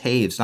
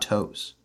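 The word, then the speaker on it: Toews: But yeah, Weinmeister, I don't know if he, it wouldn't surprise me if he went by Weinmeister just because that's anglicized, but the W in German is spoken like a V in English, Wein, not wine. This is why Jonathan Taves is pronounced Taves, not Toes.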